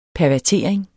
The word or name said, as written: pervertering